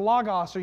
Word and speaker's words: logos: That Greek word is a word called logos, or L-O-G-O-S, logos, or you might pronounce it logos.